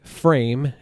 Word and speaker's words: Pfriem: Okay, so again, this is the Frame. It's spelled P-F-R-I-E-M.